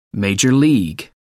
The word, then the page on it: minor league: British English